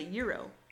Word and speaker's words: gyro: He's talking into his wallet and trying to order a gyro. Or is it called? I think it's pronounced a euro.